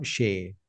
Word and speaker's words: Shay: And today we are having a great guest, you know, which is a wealth of knowledge, Dr. Sham. Again, I mispronounced the name. I'm really sorry. Dr. Sam Shay. I got it right this time.